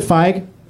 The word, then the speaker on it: Feige: Feige? – Yeah, Feige.